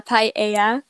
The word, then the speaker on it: paella: Sí, me gusta la paella porque es deliciosa.